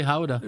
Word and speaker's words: Gouda: But the Dutch say Gouda.